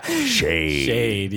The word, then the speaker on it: Sead: S E A D. How would you pronounce that? – Syed. – Syed. – Yeah, I would say Syed. – Shade. – Shade. Yeah, that's it.